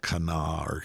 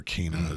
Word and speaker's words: Cana: And things like the terrain, people going, oh, the town of Cana or Cana, as it's more commonly pronounced.